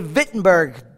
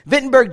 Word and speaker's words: Wittenberg: 1517, the Augustinian monk and priest and scholar, Martin Luther, approached a castle church in Wittenberg, or they say Wittenberg, W's are pronounced with V's, Wittenberg, Germany, and nailed his 95 theses to the castle door.